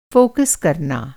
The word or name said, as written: फोकस